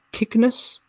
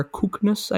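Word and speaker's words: Kyknos: Next, we have Kiknus, or Kuknus, I guess, if you want to pronounce the upsilon like that.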